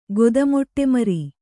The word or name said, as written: ಗೊದಮೊಟ್ಟೆಮರಿ